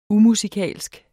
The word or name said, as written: umusikalsk